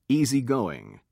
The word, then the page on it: easygoing: British English